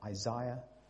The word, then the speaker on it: Isaiah: I said that right? Isaiah? Okay.